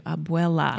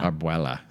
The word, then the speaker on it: abuela: Abuela. – Abuela. Sorry. Don't be angry at me for accidentally mispronouncing. – You've been mispronouncing grandmother in Spanish for as long as I've known you.